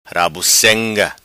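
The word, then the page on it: Rabuceng'a: patua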